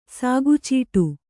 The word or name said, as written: ಸಾಗುಚೀಟು